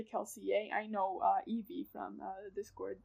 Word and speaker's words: Kelsier: So in World, Kelsier's name is like is supposed to be pronounced Kelsier. But Brandon has said, like, Brandon doesn't pronounce it this way. Brandon says Kelsier. And Brandon says, I don't say it the way people in the books would say it.